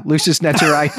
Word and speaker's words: Lucis Naturae: And Brandon, by the way, your Latin pronunciation, that's spot on. Yeah, Lucis Naturae. That's exactly it.